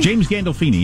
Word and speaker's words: Gandolfini: Gallofini? I don't remember his last name. – I don't know. Oh, James Gandolfini? – James Gandolfini, yeah.